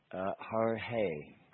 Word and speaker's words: Jorge: It is, but now that you bring it up, I was quite willing to call it Storm George, but it's actually pronounced Jorge. – Oh, Jorge, the Spanish.